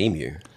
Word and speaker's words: emu: The national bird is actually the emu. – The emu? Ostrich-looking thing? – Emu. – Emu. It's spelled E-M-U. – Yes. – Emu. – Emu. – Okay, well, let me. In American speak, that's emu.